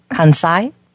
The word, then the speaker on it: Kansai: But they have a group called the Animal Rescue of, maybe you can tell me how to pronounce it, K-A-N-S-A-I, Kansai? – Kansai, yes.